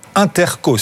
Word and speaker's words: Intercos: Le titre, Intercos, c'est comme ça qu'il faut le prononcer, Diane ? – Exactement.